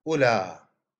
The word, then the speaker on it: أولى: إذا أتينا إلى أولى، أولى يعني هذا أيضا اسم إشارة كما مر معنا، كذلك الواو هنا تكتب ولا تلفظ.